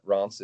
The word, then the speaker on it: Reims: You'll go straight to the main city is called Reims, and the locals say it in a tricky French way, but Reims is as close as we can get in terms of English pronunciation.